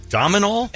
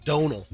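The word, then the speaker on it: Domhnall: Dominal? – And I would say... – hold on. – It's pronounced Donal. – Donal Gleason. In his accent. Dominal is what I've always heard.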